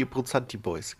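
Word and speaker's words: Abbrunzati Boys: Und zwar haben die Apozanti Boys, ich habe es wahrscheinlich jetzt wieder falsch ausgesprochen, das müsste richtig sein. – Roy Bianco? – Oder? Roy Bianco, die Apozanti Boys, genau.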